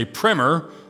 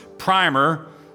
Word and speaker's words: primer: It can also be pronounced primer, like the first coat of paint you put on. But there's a different meaning. This is meaning number two. And primer, which means something like an elementary textbook, a short coverage of something.